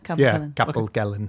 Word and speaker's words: Capel Celyn: Is that how you say it? – Capelkelen. – I think it's Capelkelen. – Yeah, Capelkelen. Okay.